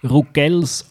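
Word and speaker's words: Ruggell: So, habe ich Rugel richtig gesagt, oder wie muss man das sagen? – Ja, mal, du hast es super gesagt. Genau so, wie du gesagt hast, ist es richtig.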